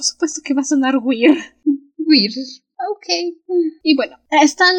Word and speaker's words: Weyr: y el weird. Ah, por cierto, weird Se pronuncia Weir.